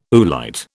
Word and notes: oolite: British English

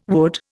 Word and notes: would: Indian

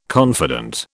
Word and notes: confident: British English

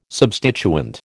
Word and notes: substituent: American English

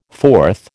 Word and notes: fourth: American English

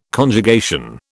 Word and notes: Conjugation: British English

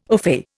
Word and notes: ofay: Indian

